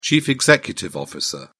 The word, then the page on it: chief executive officer: British English